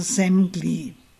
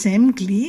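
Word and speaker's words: Semglee: Es geht um das Insulin Semgli, und das schreibt man Semgle mit 2 E hintendrein, spricht es aber ein bisschen Englisch aus, also Semgli. Und dieses Semgli ist in Österreich zumindest deshalb so etwas Besonderes, weil es das erste Biosimilar ist, das wir in Österreich bekommen.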